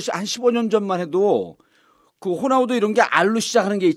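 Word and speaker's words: Ronaldo: 호나우도 맨 처음에 로날, 로날두라고 그랬어요. 로날두를 읽었었잖아요. 로날두. 네, 네, 알은 발음이 안 되는 건데.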